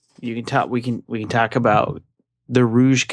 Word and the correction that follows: Rogue: Oh, it's pronounced Rogue, isn't it?